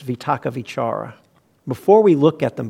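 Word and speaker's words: vitakka vicāra: Sometimes you'll hear it with a W instead of V, a vitaka vichara. That's a Burmese accent. That's how they pronounce Pali. But if you go to the international standard Pali, it's not a right or wrong, it's different pronunciations. It's a V.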